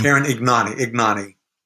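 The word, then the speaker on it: Ignagni: Karen Ignani.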